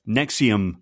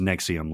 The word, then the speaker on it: NXIVM: This is the Nexium cult. It's stylized as NXIVM, but it's pronounced as Nexium, like the acid producer.